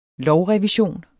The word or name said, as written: lovrevision